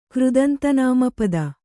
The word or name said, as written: ಕೃದಂತನಾಮಪದ